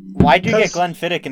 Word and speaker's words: Glenfiddich: How do you say Glenfiddich again? – Like that. You said it right. – Yes. Glenfiddich. The closing CH is pronounced as a K, but it's also glottal.